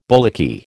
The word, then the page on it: bullocky: American English